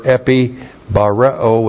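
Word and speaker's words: Epi bareo (ἐπιβαρέω): This is hina plus the present active subjunctive of the verb epi bareo with the negative may. Epi bareo. E-P-I-B-A-R-E, long O. Epi bareo.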